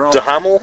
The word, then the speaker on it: Duhamel: I think that there was that scene with Josh Duhamel. Is that how you pronounce the last name? – Sure. Duhamel? – The guy from Vegas.